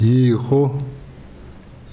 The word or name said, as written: hihho